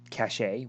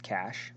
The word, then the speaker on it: cachet: I mean, I, I, I mean, I can add the fact that I feel bad for TDO because he said cachet when it's pronounced cash.